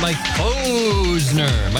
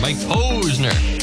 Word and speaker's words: Mike Posner: It's Mike Posner. Mike Posner.